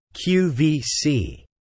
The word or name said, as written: QVC